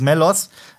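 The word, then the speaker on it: Mellos: Vielleicht wird er auch Spanisch ausgesprochen. Meos. – Meos. Nein, es ist Melos.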